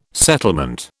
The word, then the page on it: settlement: British English